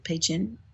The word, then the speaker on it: Pei Chun: I'm saying that right, aren't I? Is it Pei Chun? Yeah.